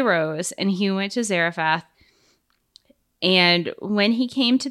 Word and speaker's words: Zarephath: Then the word of the Lord came to him, arise and go to Zarephath. How do you say that? – That was close enough.